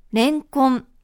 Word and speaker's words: レンコン: ノミネートをご紹介した際にお分かりいただけたと思いますが、正しいアクセントはレンコンです。 – レンコンだよね。 – すべて同じ高さで読みます。